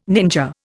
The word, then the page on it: ninja: Indian